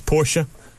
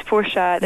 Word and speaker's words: Porsche: She thinks it's Porsche. I think it's Porsche. – It's Porsche. There's no silent E in German.